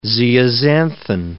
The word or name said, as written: zeaxanthin